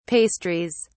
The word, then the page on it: pastries: British English